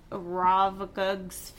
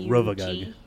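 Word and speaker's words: Rovagug: Ravagug's Fury. – Can you spell it for me? – Yeah. R O V A G. – Oh, Ravagug.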